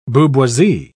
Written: booboisie

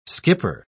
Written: skipper

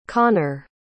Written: conor

